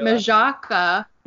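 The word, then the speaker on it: Michalka: Allie Michaela, or Allie, how do you say her Majaka.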